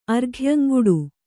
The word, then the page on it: ಅರ್ಘ್ಯಂಗುಡು: kannada